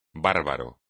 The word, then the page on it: bárbaro: espagnol